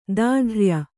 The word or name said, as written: ದಾಢ್ರ್ಯ